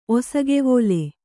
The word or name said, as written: ಒಸಗೆವೋಲೆ